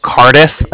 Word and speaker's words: Cardiff: Uh-huh. – I'm calling with the Bank of Cardiff, and it's spelled C-A-R-D-I-F-F, as in Frank Frank, so it's like car and then different.